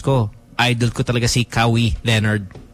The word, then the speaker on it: Kawhi: Well, it's spelled Kawhi, but it's Kawhi. And if you're a fan, you would know that.